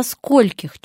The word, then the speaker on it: скольких: В слове «скольких» ударение всегда падает на первый слог и никогда никуда не убегает.